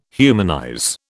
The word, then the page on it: humanize: British English